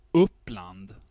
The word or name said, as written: Uppland